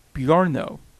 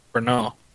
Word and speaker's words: Brno: I'm going to pronounce this one incorrectly now, too. The Brno circuit. – Brno. – Yeah. That makes more sense.